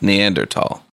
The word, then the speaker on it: Neanderthal: It does, so it's pronounced Neanderthal. – It's Tahal, but you just say tall.